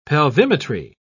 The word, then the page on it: pelvimetry: British English